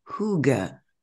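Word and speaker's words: hygge: But you, I've just found out you pronounce it hooga Yes.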